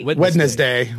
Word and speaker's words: Wednesday: Wednesday. – Wednesday. A minute. That syllable doesn't belong in there.